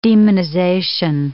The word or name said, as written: demonization